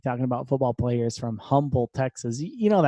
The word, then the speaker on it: Humble: Like, right outside of Houston, there's a town called Humble. It's spelled with an H, spelled with a, you know, whatever. It's spelled with an H, but we all call it Humble. Instead of Humble, we call it Humble.